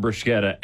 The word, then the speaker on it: bruschetta: And the bruschetta. – So you're getting bruschetta every time.